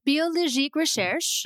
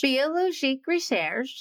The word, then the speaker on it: Biologique Recherche: We learned how to correctly pronounce the name. – Biologique. – And I was close. Yeah, you are. Biologique Recherche. – Biologique Recherche. – There you go.